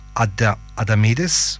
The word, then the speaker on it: Adamidis: He called his friend Michael Adamidis. Did I say this properly? – Yeah, Adamidis.